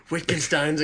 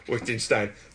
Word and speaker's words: Wittgenstein: That's when my brain said Lichtenstein instead of Wittgenstein. – But... – I think that time you still said Wittgenstein. – Yeah, isn't it a W? But it's German, yes, but so it's pronounced as a V.